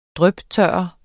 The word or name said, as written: dryptørre